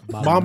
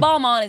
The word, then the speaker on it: Balmain: Balmain. – That's the right way to pronounce it. – Balmain. – It's Balmain is actually the correct way.